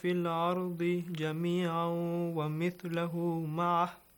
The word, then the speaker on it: جميعا: جميعا جميعا. You extend the sound for two beats.